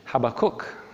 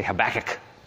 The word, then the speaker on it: Habakkuk: I don't even know how to pronounce his name. Habakkuk. Some people say Habakkuk. The prophet Habakkuk.